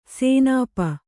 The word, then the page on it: ಸೇನಾಪ: Kannada